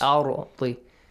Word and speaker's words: أرض: It would be heavy. – Heavily pronounced. Yes. Arti. It is sakin. Doesn't have any harakat. No, doesn't have any fatha. But since there is a fatha sound before, it has to be pronounced heavily.